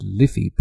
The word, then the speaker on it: Life: And the Irish name for the River Liffey that runs right beside this well is called An Liffey, and it's spelt L-I-F-E. It's pronounced Liffey, but it's spelt L-I-F-E, which is the Waters of Life.